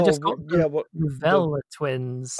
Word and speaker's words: Velle: So it's spelled V E L L E, but I have I, I get the impression they want you to pronounce it Vella.